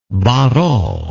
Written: barrault

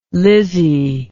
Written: Lizzie

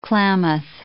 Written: Klamath

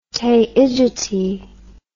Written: Taygete

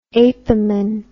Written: apamin

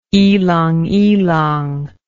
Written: ilang ilang

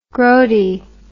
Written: groaty

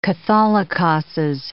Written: catholicoses

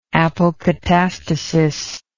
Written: apokatastatic

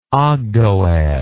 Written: ogdoad

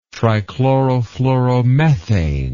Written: trichlorofluoromethane

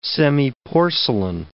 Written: semiporcelain

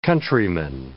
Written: countryman

